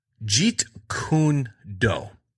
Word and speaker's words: Jeet Kune Do: Jeet Kune Do.